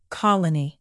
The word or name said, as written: colony